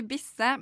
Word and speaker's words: Bisse: Der hørte vi Bisse med legeavtale 17.08.97. Var det riktig eh, uttalt? – Ja, det var helt riktig.